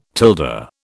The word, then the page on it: tilde: British English